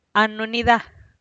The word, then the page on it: a nu ni da: Teotitlán del Valle Zapotec